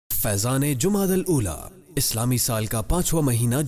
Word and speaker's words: جمادی الاولی: درست نام اور صحیح تلفظ جمادی الاولی ہے۔